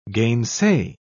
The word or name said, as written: gainsay